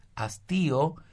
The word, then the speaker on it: hastío: el hastío, con H y acento en la I, hastío, se instala en la relación de pareja alrededor de los 7 años.